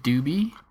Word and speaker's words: Dubhe: They're coming out with their Imperial Black IPA called Doobie. Pronounced, or it's spelled D-U-B-H-E. It's named after Utah's Centennial Star, which is classified as a red giant.